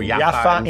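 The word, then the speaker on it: Yaffa (يافا): And he's just been a great source of knowledge about this wonderful diaspora food, which we're enjoying here in Yaffa, or Yaffo, depending on how you pronounce it, I guess. – Yaffo. – Yaffo. – Yaffo. – Okay, Yaffo in Hebrew. – Yaffa in Arabic. And Jaffa in English.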